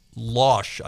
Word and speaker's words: Lausch: attorney for the Northern District of Illinois is a guy by the name of John Lausch, I guess is how you pronounce it, L-A-U-S-C-H.